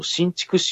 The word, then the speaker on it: 新竹: はい、ね、例えば新竹市、新しい竹と書いて新竹っていう町があるんですけど、ここなんかはもうずいぶん前から桜の植樹一生懸命なんですよね。